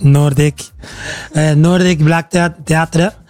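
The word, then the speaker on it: Nordic Black Theatre: Nordic Black Theater, lo pronuncio en noruego, es Nordic Black Theater, y allí vamos a tener un concierto, el cumbiódromo, le hemos llamado.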